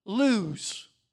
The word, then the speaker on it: Luz: As a matter of fact, technically, Luz is pronounced Luz. It's L-U-Z, but it's pronounced Luz. And in English, that's L-O-S-E, Luz.